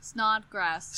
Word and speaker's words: Snodgrass: I never knew how to say your last name. – How do you think it was? – Snoggers. – Or like one more time. – Snowgrass? – Snodgrass. – Snodgrass.